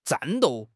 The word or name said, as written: 战斗